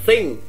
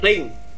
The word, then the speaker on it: thing: Đọc là thing, thing. Đừng có thờ thôi, đừng có thờ. Em có cảm giác ở đầu nó có một cái tờ không? – Đúng rồi. – Em đừng để lưỡi chạm răng. Đừng để lưỡi chạm răng. Sẽ đọc là thing.